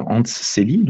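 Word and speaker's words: Selye: Donc Célie, euh, Hans Célie, donc cet auteur, Célie, ça s'écrit S-E-L-Y-E, e, si vous voulez aller regarder un peu sur Internet.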